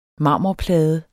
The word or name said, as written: marmorplade